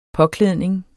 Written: påklædning

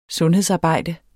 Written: sundhedsarbejde

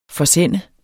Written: FORSEND

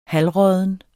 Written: halvrådden

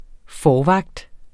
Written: forvagt

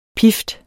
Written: pift

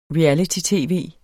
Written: reality-tv

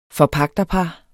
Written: forpagterpar

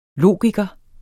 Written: logiker

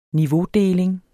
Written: niveaudeling

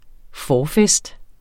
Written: forfest

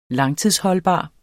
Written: langtidsholdbar

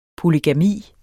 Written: polygami

